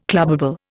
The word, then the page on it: clubbable: Indian